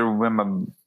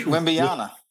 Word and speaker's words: Wembanyama: My honorable mention is going to be to Victor Wembanyama. The guy from Wembanyama.